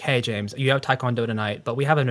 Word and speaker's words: taekwondo: And did I say that right? Taekwondo? – Taekwondo. – Taekwondo, okay. When I did taekwondo and I got like through a bunch of, you know, white belt stuff.